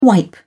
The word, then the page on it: wipe: British English